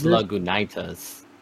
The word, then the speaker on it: Lagunitas: I think you pronounced it wrong, by the way. I think it's Lagunitas.